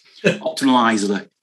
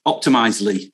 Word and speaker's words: Optimizely: Opt, I, can never pronounce that quite right. Optimizely. Optimizely. – That's yeah, that's correct.